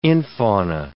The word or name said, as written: infauna